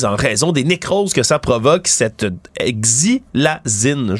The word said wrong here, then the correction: xylazine: je vais bien le prononcer, la xylazine, aussi appelée euh, sur la rue la trank, des tranquillisants qu'on utilise dans les domaines vétérinaires, entre autres, pour mhm.